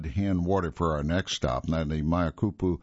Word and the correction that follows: Makapu'u: Lighthouse, or Makapu'u, let me change that pronunciation, Makapu'u Lighthouse on the southeast tip of Oahu, is said to have the largest lighthouse lens in the United States.